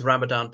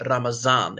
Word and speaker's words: Ramadan: So that is Ramadan. Some people pronounce it Ramazan.